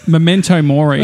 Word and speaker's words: memento mori: Memore Mento or something. – Jeez, you've got a real way of butchering it. – Memento Mori is what it's called. – No, that was back to front.